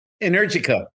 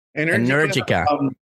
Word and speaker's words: Energica: Energica. – Energica.